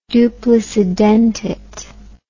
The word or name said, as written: duplicidentate